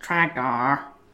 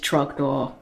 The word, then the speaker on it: Trogdor: My first one is Trogdor, or if you watch the video, it's mispronounced Tragdor repeatedly and then corrected to Trogdor several times,